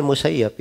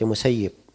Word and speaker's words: al-Musayyab: Dibacanya Al-Musayyab ya, di fathah ya-nya. Bukan dibaca Musayyib. Iya. Disebutkan beliau tidak suka kalau disebut Musayyib. Tapi disebutnya Musayyab. Dan kita juga dengarnya dari guru-guru dibacanya Musayyab.